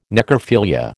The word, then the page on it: necrophilia: American English